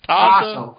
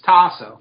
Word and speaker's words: Taz: Is it Tazo or Tazo? Tanazo? – Are you kidding me? It's been fucking 7 years and you don't know my name? – I know how to spell it, but I've never actually... How do you actually pronounce it? Is it Tasso? Tasso. It's Tasso.